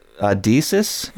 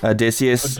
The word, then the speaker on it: Odysseus: The Odysseus, I'm butchering the name. – Odysseus.